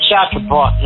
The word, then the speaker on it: Chartres-Abbott: Everyone's pronouncing it wrongly, by the way. – Yeah, but it's Chartrebot. That's how the French would say, you know, Chartrebot.